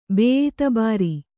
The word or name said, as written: ಬೇಯಿತಬಾರಿ